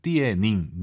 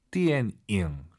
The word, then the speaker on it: 电影: 3월부터 저희와 함께 하신 분들은 영화 발음 이제 아실 것 같아요. 띠에닝, 니은 발음 들리면 안 돼요. – 电影。 – 띠엔잉.